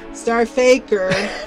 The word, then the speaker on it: STRFKR: I can't really say the name of it, but it's spelled S-T-R-F-K-R, Star Effer. – Star Faker. – Star Faker. It's Star Faker, right.